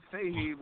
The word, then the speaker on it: Fahey: Well, Fahey would be... – Okay. – The way most Americans say it.